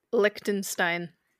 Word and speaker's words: Liechtenstein: I think it's Liechtenstein? Liechtenstein? – Liechtenstein.